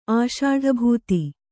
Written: ಆಷಾಢಭೂತಿ

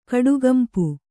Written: ಕಡುಗಂಪು

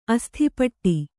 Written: ಅಸ್ಥಿಪಟ್ಟಿ

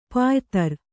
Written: ಪಯಿತ್ರ

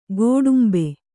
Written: ಗೋಡುಂಬೆ

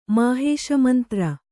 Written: ಮಾಹೇಶಮಂತ್ರ

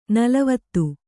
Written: ನಲವತ್ತು

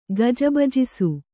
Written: ಗಜಬಜಿಸು

